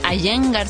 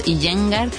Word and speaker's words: Iyengar: Yo considero que mis bases son del estilo, bueno, Iyengar se pronuncia, pero en español a veces le decimos Iyengar.